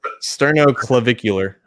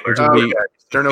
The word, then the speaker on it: sternoclavicular: They did classify sternoclavicular, I think. – Sternoclavicular.